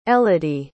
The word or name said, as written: Elodie